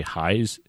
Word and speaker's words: Heyes: Well, I feel like I should say Heyes. It's spelled like... – How's it spelled? – It's H-E-Y-E-S. So it's like Hayes, but with the E. Two E's. It's like, hey, well, I'll say Heyes.